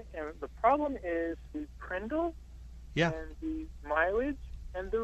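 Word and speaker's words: PRNDL: If you pronounce that phonetically, it's called the Prindle.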